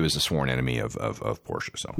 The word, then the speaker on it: Porsche: It is Porsche. It's German. Do it. It's not Porsche. It's Porsche. Trust me.